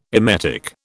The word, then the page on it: emetic: British English